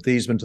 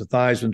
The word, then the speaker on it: Theismann: But we think just by changing the pronunciation for your last name from Thiesman to Thiesman to rhyme with Heisman, we believe we can get you that trophy.